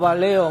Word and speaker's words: Baleo: Nino Rodríguez Miguel, del grupo Baleo, muy buenos días. – Hola, buenos días. – ¿He pronunciado bien el grupo con el acento? – Sí, muy bien, bueno.